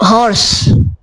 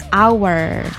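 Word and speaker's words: hour: Okay, so when it's related to time, so actually this should be pronounced with our. – Our. – The H is silent.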